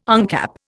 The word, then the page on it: uncap: Indian